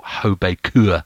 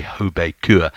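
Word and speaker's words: HB Køge: Hobekur from Denmark, as I found out they were pronounced. It's written HB Koga, but apparently you say Hobekur.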